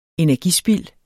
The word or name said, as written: energispild